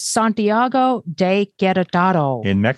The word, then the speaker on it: Santiago de Querétaro: As Bill reminded us several times that we mispronounced it, it's Santiago de Querétaro.